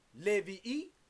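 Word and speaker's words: Levi'i (לויים): The word Levites, 3881. You pronounce it Levi'i.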